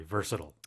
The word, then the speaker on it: versatile: they're very versatile. – Yeah, definitely. Versatile, versatile. 발음 둘다 되고요.